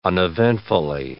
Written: uneventfully

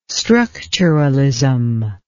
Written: structuralism

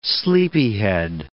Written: sleepyhead

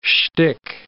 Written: schtick

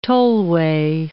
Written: tollway

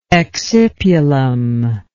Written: excipulum

